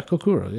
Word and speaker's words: Kokura: How do you pronounce that? Kurkura? K-O-K-U-R-A. Kurkura. – Yeah, Kurkura.